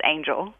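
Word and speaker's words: Angyal: When this happened, I read a piece in The Nation magazine by Chloe Angell. Did I say your name correctly, Chloe? – Actually. – I did. – No, it's pronounced Angel.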